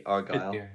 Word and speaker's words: Argyle: It's a hard G. Argyle.